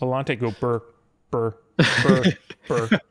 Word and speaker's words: Volante: How do you pronounce it? The Volta? For some reason, I was thinking it was a longer word than that. – What? The Volante? – Volante. That's it.